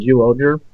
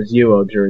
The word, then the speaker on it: Zyuohger: Doubutsu Sentai Zyuohger, or Zuoger. It's kind of weird how you pronounce that.